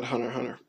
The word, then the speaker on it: Hunter x Hunter: It has an X in it, but apparently you're not supposed to pronounce the X. I think that's dumb, but that... – See? And yet he still said Hunter Hunter.